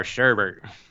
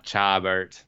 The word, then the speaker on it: Chabert: Lacey, I'm calling it Chabert. – Chabert. – I'm going to call her Sherbert. – Sherbert. It's spelled Chabert. – Lacey Sherbert. – Sherbert. – Nah, Chabert.